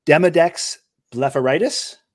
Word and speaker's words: Demodex blepharitis: I'm going to have you talk a little bit about, and I hope I'm pronouncing this correctly, Demodex blepharitis? – Yes.